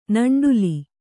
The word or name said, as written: ನಂಡುಲಿ